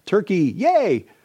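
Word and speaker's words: Türkiye: It's like Turkey, yay!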